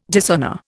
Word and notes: dishonour: Indian

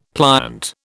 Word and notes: pliant: British English

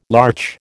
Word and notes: larch: American English

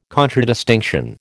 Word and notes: contradistinction: American English

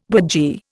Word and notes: burgee: Indian

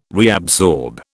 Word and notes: reabsorb: British English

